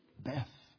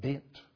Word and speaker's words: Beth: The first part of that word, Beth, as we say it, is actually corresponds, or it is the Hebrew letter B, because the Hebrew letter for B, as we say things, is pronounced Beth. In fact, the Hebrew pronunciation would be a little different from that, Beit.